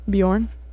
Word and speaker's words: Bjorn: Is that how you say it? Bjorn? Mm-hmm. Probably, like Bjork.